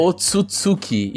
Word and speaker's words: Otsutsuki: Os Otsutsuki, eu sempre esqueço como é que se pronuncia. – Otsutsuki, eu acho que é. – Otsutsuki, isso. Tsutsuki.